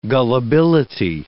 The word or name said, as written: gullibility